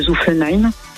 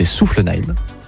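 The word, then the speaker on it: Soufflenheim: Alors, si je peux me permettre, une toute petite rectification, sur la prononciation, c'est Zuffenheim.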